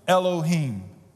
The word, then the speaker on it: Elohim: The Hebrew word here is Elohim, just like it's spelled, H-I-M at the end, Elohim.